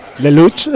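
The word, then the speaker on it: Lellouche: Y Gilles Lelouch, no sé si lo he dicho mal, pues perdóneme, señor Gilles. – Lelouch.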